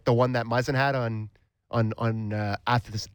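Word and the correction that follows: Athanasiou: Oh, I can't ever say his name. Athanasiu. – Athanasiu nailed it.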